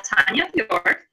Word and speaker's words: Tanya Bjork: And my special co-host, Tanya Bjork.